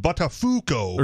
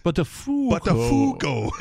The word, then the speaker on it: Buttafuoco: It's like, it's pronounced Buttafuoco. – Right, yes. Buttafuoco.